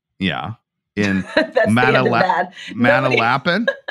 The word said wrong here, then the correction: Manalapan: It's pronounced Manalapan. – Why correct me this early? There's going to be many more mistakes like that. – You sounded silly. Manalapan. – Yeah, that sounds way better.